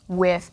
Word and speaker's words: Wythe: George Wythe, and we should say Wythe is actually spelled more like wive, but it's pronounced Wythe.